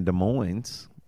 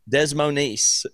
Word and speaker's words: Des Moines: I'm pretty sure it's Des Moines. I don't think it's a. Is there? There's never a silent S.